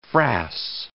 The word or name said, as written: frass